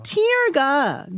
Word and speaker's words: tear: tear가 눈물이란 뜻이에요. Mm, 근데 T-E-A-R인데 이 단어는 두 개의 발음이 있거든요. – Yeah, right. – 눈물은 tear. tear. 근데 찢어짐은 tear.